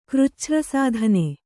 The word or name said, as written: ಕೃಚ್ಛ್ರಸಾಧನೆ